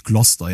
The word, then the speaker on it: Gloucester: Ich hab's, ich hab's, ich hab's gerade auch äh, falsch ausgesprochen, ist natürlich Gloucester, ja, also.